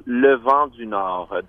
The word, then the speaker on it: Le Vent du Nord: The band is, the name is called Le Vent du Nord, the Wind from the North, and it's a folk music band from Quebec.